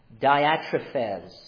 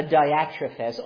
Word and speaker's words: Diotrephes: Dia, dia, or D-I-A, is how I think of it. T-R-U-P-H-E-Z. That's how I write it down so I can remember how to say it. Diatrephes.